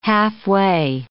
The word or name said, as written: halfway